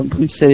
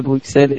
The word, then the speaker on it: Bruxelles: Là, j'ai Auxerre, Bruxelles, on dit bien Bruxelles et pas Bruxelles, et donc le FAO.